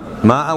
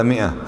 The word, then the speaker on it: مئة: ماء ولا مئة، ثلاث مئة، واضح؟ انساهم اليوم رايح، ما في اسمه ماء، هذه الألف فقط حتى تتميز كلمة مئة من كلمة منه، لا لأنها تلفظ.